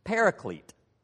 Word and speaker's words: paraclete: Well, the Greek word is pronounced here that John uses, paraclete, not to be confused with parakeet. That's a bird.